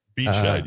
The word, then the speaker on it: Biedscheid: The MLG connection, I apologize if I'm mispronouncing this, but Brian Bideshide. – Let's spell it. – B-I-E-D-S-C-H-E-I-D. – Bideshide, yeah. – Yeah.